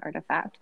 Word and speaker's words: RTFKT: Click on that form and make sure you put the special code of RTFKT, or as our Discord just found out, is pronounced Artifact.